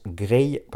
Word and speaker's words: Grej: j'avais dit que le beatmaker s'appelait Grege, parce que ça s'écrivait G-R-E-J. Et en fait, il m'a envoyé un message pour me dire que ça se prononce Grey, parce que c'est, voilà, c'est comme ça qu'on le prononce en Albanie, et il est d'origine albanaise, donc voilà, c'est, il m'a apporté cette rectification et j'ai voulu la faire.